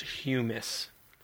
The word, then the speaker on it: hummus: I believe it's pronounced humus.